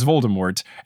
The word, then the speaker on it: Voldemort: So as far as I'm concerned, the conventional way to say it is Voldemort, and if you don't pronounce the T, you are trying too hard, and I will judge you.